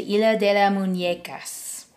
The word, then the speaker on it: Isla de las Muñecas: Is in Spanish, the Isla de las Muñecas.